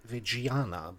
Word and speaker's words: Reggiana: Und, ähm, und, ganz am Anfang ähm, nur der Vollständigkeit halber beim AC Parma und vorher beim AC Reggiana, würde ich das ah. mal aussprechen. Ja, ja, ist richtig, ist richtig.